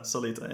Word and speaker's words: Solita: You say the name. I didn't ask you how to say it. – Solita, yeah. – Solita, right. Okay. So it says like it reads.